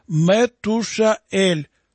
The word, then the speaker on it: Метушаэль: В действительности имя Каианита Мафусала произносится как Метушаэль, просящий смерти, проживший неопределенное малое количество лет.